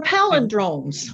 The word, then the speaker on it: palindrome: They're palindromes. – Palindrome, that's how you say it. Sorry about that. So do L-E-N-D-R-O-M-E.